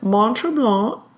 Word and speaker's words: Mont-Tremblant: Mont-Tremblant.